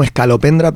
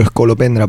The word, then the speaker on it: Escolopendra: Es como Escalopendra, pero Escolopendra, porque se emperraron en que fuese así, pues para adelante, yo qué sé.